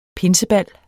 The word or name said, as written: pinsebal